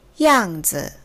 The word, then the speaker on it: 样子: 样子，y a n g 사성이고요. 뒤에는 z e 경성이에요. 양즈 양즈 모양, 상태, 형세 등을 뜻하는 명사입니다.